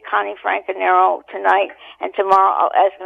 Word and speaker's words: Franconero: He said, how do you pronounce your name again? So I said, Franconero, as if teaching him a foreign language.